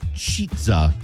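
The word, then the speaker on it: Chizza: It's spelled C-I-C-H-I-Z-Z-A, Cheetza.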